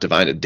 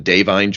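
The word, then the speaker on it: Da'Vine: I do love Oscar season because you actually learn how to pronounce names now, and it's not Divine. Divine Joy Randolph for the holdovers.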